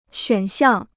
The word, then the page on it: 选项: xuǎn xiàng